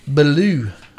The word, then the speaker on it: Ballou: And then the Mini Late Models was Stephen Ballou. I think that's how you say his name. – Sounds right.